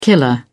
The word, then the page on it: killer: British English